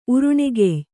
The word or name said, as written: ಉರುಣೆಗೆಯ್